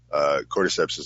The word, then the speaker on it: cordyceps: Cordyceps is a big one for cancer. If I'm saying that right, cordyceps. – Yep.